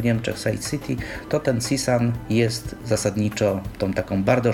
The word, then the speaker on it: CSUN: Tak, to jest de facto skrót, natomiast skrót ten się stał takim, po prostu takim sloganem, natomiast pisze się to CSUN, po angielsku CSUN, tak to oni czytają,